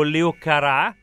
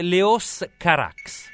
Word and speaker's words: Carax: quello che io pronunciavo Leo Carà quello di Holy Motors, sì. Gli amati, si dice Leos Carax.